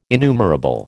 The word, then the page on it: innumerable: American English